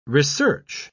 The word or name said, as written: research